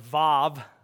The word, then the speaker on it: Vav: In Hebrew, it's pronounced Vav. The W makes the V sound, and that is the sixth letter of the Hebrew alphabet.